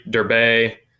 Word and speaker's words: Derbe: So after they are once they leave Lystra after this, they're going to go to Derbe. That's how you say it in Greek. It's not Derb.